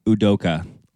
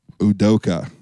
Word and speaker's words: Udoka: with Ime Udoka. – Udoka.